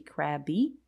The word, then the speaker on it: Crabbe: It is by Tony Crabb and it's spelled C-R-A-B-B-E. So it could be Crabby, but I'm going to say it's Crabb.